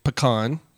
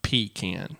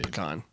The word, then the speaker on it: pecan: And so his theory is that's why certain parts of the U.S. that are trying to take a French word and learn how to say it end up emphasizing the wrong syllable and saying something like pecan instead of pecan.